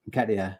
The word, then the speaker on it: Nketiah: Nketiah.